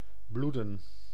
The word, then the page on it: bloeden: [blu.dǝː]